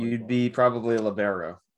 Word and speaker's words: libero: What's a libero? – At least you said it right.